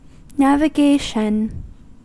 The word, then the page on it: navigation: American English